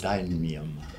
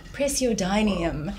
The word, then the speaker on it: praseodymium: Praseodymium. – Praseodymium, which is a soft metal, and they are baked into the crystal.